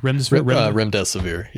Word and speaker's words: Remdesivir: Remdesivir, yeah.